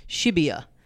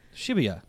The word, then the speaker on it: Shibuya: And he's lived there for a little while and he was pronouncing it Shibuya. – Shibuya. – But like just realizing I've been, like, I thought I was saying it right, but realizing I've been putting a real Aussie twang on there.